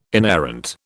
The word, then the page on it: inerrant: British English